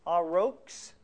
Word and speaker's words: aurochs: That animal that is now believed extinct is A-U-R-O-C-H-S. Connie, how do you pronounce that? Aurox or something like that? A-U-R-O-C-H-S. H-S. – Oh, the aurochs. Aurochs, okay. The aurochs is what this animal we believe is being talked about as the wild ox.